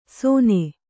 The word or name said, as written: ಸೊನೆ